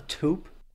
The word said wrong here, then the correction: toupee: Carson, you want me to rock? – Well, a toupee is how it's pronounced, but yes. – You want me to go full George Costanza and rock a toupe? – Well, again, a toupee, but yes,